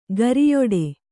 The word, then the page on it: ಗುರಿಯಿಡು: guriyiḍu